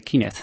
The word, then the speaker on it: Kinect: Que en Microsoft, lo pronuncio bien Kinect, para que quede claro, que en Microsoft piensan que este es el mejor nombre con el que identificar a su gran obra.